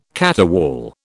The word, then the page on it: caterwaul: British English